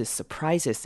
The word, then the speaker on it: surprises: Surprises，之前好像前几个礼拜的也有提过这个字 surprises 的念法。 That is what surprises us. Surprises us. 好，surprises 记得后面 s e s 念成 z 的声音。